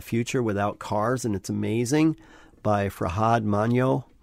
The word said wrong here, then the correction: Manjoo: How do you say that? M-A-N-J-O-O. Fran, give me a pronunciation check on that one. – I would go Farhad Manjoo.